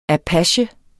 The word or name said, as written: apache